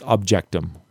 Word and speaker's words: objectum: I believe it's objectum is the correct way to say it.